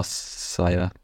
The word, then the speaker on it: Sire: Aber spricht man das auch Sir aus oder Sire? No Sire? – Ich würde sagen No Sir, aber die können auch sagen No Sire, finde ich schön. Sire finde ich schöner.